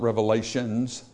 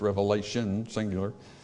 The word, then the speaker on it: Revelation: It's not Revelations, is it? That's how a lot of people pronounce it. It's Revelation, singular.